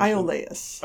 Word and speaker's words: Iolaus: Iolaus.